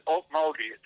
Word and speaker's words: Okmulgee: is the Rural Water District's number 6 of pronounce that again? – You pronounce it Okmulgee. It's an Indian name.